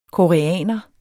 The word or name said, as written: koreaner